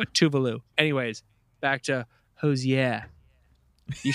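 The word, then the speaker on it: Tove Lo: When I was in Sweden, I found out that Tovlo's name is actually pronounced Tuvalu, but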